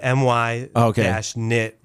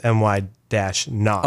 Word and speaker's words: Minot: They put my-nit versus my-not.